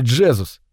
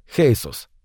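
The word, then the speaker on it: Jesús: Это было довольно давно, но трудно забыть детектива ростом 6 футов 6 дюймов по имени Джезус, даже если оно на самом деле произносится как Хейсус.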